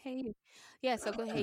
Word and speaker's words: Hayu: Is that what it's pronounced? – Yeah. – Wow. – What did you think it was? – Hi You. Who You? Who You? – It actually says H-A-Y-U. Hey You.